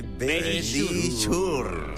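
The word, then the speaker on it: Verisure: Nuestros amigos de Bellisur.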